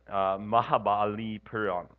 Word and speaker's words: Mahabalipuram: Mahabalipuram.